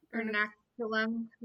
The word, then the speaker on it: Ernakulam: Is that how you pronounced it? Ernakulam. Yeah. – Yeah, that's a better way of pronouncing it than I ever could.